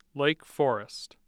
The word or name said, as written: Lake Forest